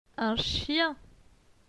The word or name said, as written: Un chien